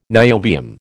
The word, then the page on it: niobium: American English